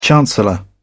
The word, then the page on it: chancellor: British English